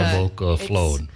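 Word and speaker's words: Jólabókaflóð: Yola Boca Float. – It sounds like it starts with a Y, but it's a J.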